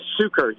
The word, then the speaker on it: Sucher: Yes, it's Sucher, yes. That's Sucher. Various pronunciations that you're hearing right now, yeah, Sucher.